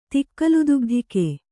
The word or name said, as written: ತಿಕ್ಕಲುದುಗ್ಧಿಕೆ